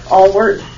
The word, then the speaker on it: Alwert: My name is Lisa Allward, and it's spelled A-L-W-E-R-T.